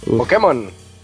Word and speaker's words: Pokémon: Y sí, es Pokémon con acento en la E y se pronuncia como lengua española. Saludos de su amigo Aldeborán. – Pokémon.